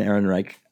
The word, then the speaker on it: Ehrenreich: It's Alden Ehrenreich.